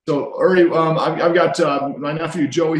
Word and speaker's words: Uri: And you know, it's pronounced Uri, not Yuri. I was sorry to get that messed up because I've got some friends who pronounce Yuri, but it's Uri.